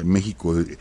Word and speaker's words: México: Ya México, aunque se escribe con X, pero se pronuncia con J, con la J española.